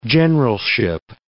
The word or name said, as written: generalship